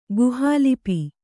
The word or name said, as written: ಗುಹಾಲಿಪಿ